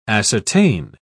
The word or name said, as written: ascertain